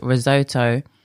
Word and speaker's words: Risotto: By the way, before I go on, I did ask Jackie how to pronounce her name. It's spelt Risotto. She said it's an Italian name that has been Americanized. So, of course, the way she pronounces it is Risotto.